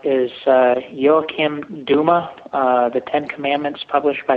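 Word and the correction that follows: Douma: Oh, Joachim Dauma. – Dauma, okay, I figured I was going to butcher it.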